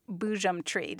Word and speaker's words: Boojum Tree: So the name of your book is Boojum Tree. Did I pronounce that correctly? – That's right.